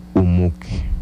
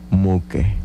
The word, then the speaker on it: umuque: En lengua yocotán se pronuncia umuque. Repito nuevamente, umuque.